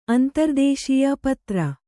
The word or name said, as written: ಅಂತರ್ದೇಶೀಯ ಪತ್ರ